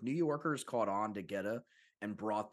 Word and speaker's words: goetta: It's called goetta. Please tell me I'm pronouncing that right. Yeah. – Yeah. – And you got it.